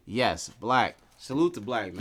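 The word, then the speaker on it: 6lack: You're saying his name wrong, because I was going to correct you on Twitter, but I didn't want to. – No, go ahead. How do I pronounce his name? – It's Black. – Black. It's just Black, not Six Black.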